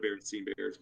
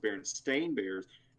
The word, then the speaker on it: Berenstain: Well, I've always called it Berenstein Bears, but it's actually pronounced Berenstain Bears. There's been a whole argument with that because per, the pronunciation stuff. People, some people say it's Stein, some people say it's Stain.